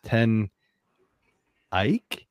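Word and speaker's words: Ten Eyck: So I'm going with 10 Eyck Brewing Company.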